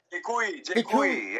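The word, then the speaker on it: Děkuji: Is that how you pronounce it? Dekuji? I'm using Google Translate to say thank you. – Dekuji. Yeah.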